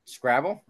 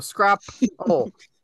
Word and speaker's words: Scrapple: Scrabble? Like their game? – Scrapple? No, Scrapple. Like apple rhymes with Scrapple.